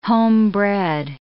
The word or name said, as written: homebred